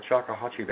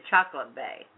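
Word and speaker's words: Choctawhatchee: Oh, you finally said it right. – Well, I always say it right. Haven't heard you say it yet. – Well, I don't. I said Chocolate Bay. – Chocolate Bay. Well, it's not chocolate, though.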